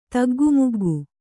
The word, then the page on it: ತಗ್ಗುಮುಗ್ಗು: taggu muggu